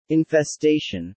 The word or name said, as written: infestation